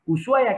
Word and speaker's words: Ushuaia: Ushuaia o Ushuaia, como prefieren ustedes pronunciarlo, nosotros los argentinos la H la hacemos muda, así que le decimos Ushuaia, que es la ciudad del fin del mundo, es la capital de Tierra del Fuego.